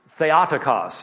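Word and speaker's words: Theotokos (Θεοτόκος): The second dogma is Mary as Theotokos. Now, The modern Greek pronunciation would be Theotokos, but that's not how I was taught, so I recognize in modern Greek it would be pronounced differently.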